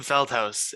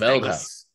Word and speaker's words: Feldhaus: Tim Feldhaus. It's Feldhaus. Anglicized Feldhaus. Dutch.